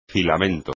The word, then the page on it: filamento: espagnol